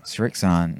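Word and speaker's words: Srixon: Is that how you say it? – Strixon. Mm-hmm. Sure is.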